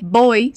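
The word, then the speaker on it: voy: Apesar de se escrever com V, boi se diz assim, com esse som de B, ok?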